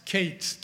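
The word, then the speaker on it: Kates (קץ): The Hebrew word there is, it's spelled Q-E-T-S in English. When it's translated from the Hebrew to English, Q-E-T-S, and it's pronounced Kates, K-A-T-E-S, or Kates, till the end.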